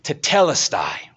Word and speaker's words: Tetelestai: Tetelestai, right? I mean, I imagine him dying. Can you imagine? Tetelestai.